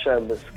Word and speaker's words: Shambles: Si chiamerà Shambles, detto bene? – Sì, Shambles, che vuol dire caos.